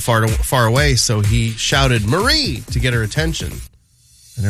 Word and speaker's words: Maree: Tom Holland, her husband, I guess calls her by her middle name, Marie. But it's spelled M-A-R-E-E. Marie, right?